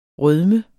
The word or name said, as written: rødmen